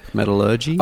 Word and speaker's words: metallurgy: Oh, see, I always said Metallurgy. Metallurgy. – Okay. – Metallurgy. – Okay, that's a perfect... No, that's the way it should be said. I foolishly pronounced it differently.